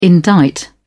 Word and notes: indict: British English

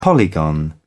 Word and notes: polygon: British English

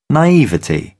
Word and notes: naivety: British English

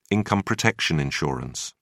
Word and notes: income protection insurance: British English

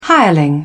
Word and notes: hireling: British English

